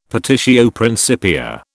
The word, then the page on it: petitio principia: British English